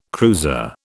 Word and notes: cruiser: British English

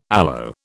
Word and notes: aloe: British English

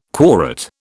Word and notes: quorate: British English

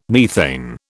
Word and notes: methane: British English

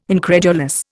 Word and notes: incredulous: Indian

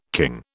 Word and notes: king: British English

